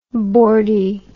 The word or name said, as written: boardy